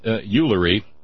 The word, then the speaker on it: Ullery: And then Ullery, which rhymes with foolery, forwarded links from the Daily Current, the one that that Tim tried to get me with yesterday.